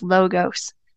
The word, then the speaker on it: logos: So the word translated to the ancient Greek language is logos.